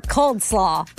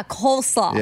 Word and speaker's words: coleslaw: Or cold slaw. – Oh, cold slaw. – It's spelled cold. – Cold slaw? No, it's not spelled cold. – C-O-L-E. – Yeah, coleslaw.